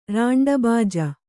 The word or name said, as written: ರಾಂಡಬಾಜ